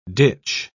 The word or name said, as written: ditch